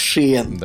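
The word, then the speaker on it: Sheehan: у него фамилия пишется так, чтобы прочитать ее как Шиен. Да? – Это я сейчас, подожди, проверю. – Ну, типа у меня того. же все открыто. – Поэтому я... S-H-E-E-H-A-N.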